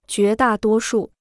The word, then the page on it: 绝大多数: jué dà duō shù